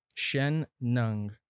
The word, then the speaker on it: Shen Nung: Say his name again. – Shen Nung. – Shen Nung. N-U-N-G.